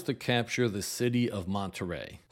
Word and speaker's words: Monterrey: This is a different Monterrey. Although pronounced the same way, they are spelled differently. Monterey, California has one R in it, Monterrey, Mexico has two R's.